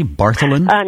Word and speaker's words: Bartholin: Bartholin? – Yes, Bartholin gland.